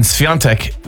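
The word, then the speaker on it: Świątek: Is in Sviantek with a little apostrophe underneath is pronounced on, not a.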